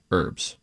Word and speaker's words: herbs: So you don't pronounce the H when you're referencing an ingredient in a dish, herbs.